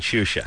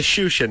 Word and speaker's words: Xuxa: Get on the internet and go find Shusha. – How do you spell it? – X-U-X-A. – X-U-X-A? – Yeah.